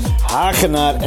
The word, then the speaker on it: Wagenaar: En I pronounce it very Dutch with the hard G. The G. Hagenaar en Albrecht, because it sounds Dutch.